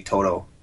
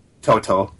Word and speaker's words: Toto: Who's paying that kind of money to see Toto? – You didn't say it right. – Toto.